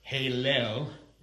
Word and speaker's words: Heylel: It's, you would pronounce it Halel. H-E-Y-L-E-L is the transliterated form of that. It's almost like hell, yeah.